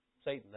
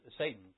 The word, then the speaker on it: Satan: Now, the word adversary, interesting word, because the Hebrew word behind adversary is spelled this way, S-A-T-A-N, Satan. Satan, that's the word. Satan is a Hebrew word, it means adversary. And so that's the Hebrew word here. Satan be the pronunciation, but Satan. Satan, we anglicize it.